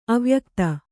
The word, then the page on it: ಅವ್ಯಕ್ತ: avyakta